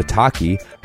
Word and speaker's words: Otaki: And the Otaki, rhymes with souvlaki, is an aggressive high-performance shoe designed to hold the downturned shape even after repeated lashings.